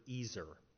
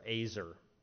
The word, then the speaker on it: ezer: This word for helper is the Hebrew word ezer. Or Azer, I think is how it's pronounced. It's E-Z-E-R, transliterated into our English.